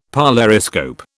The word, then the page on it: polariscope: British English